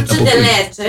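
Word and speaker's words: dulce de leche: Τσεντελέτσε, έτσι προφέρεται, είναι ισπανικό αυτό.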